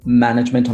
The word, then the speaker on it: management: So management on the first syllable, performance on the second syllable, for example.